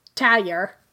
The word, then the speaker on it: Tahyo: It's called the Tayo Tavern, but it's spelled T-A-H-Y-O. – Okay. – Which somehow in Creole is pronounced Tayo.